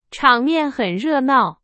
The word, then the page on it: 热闹: Chinese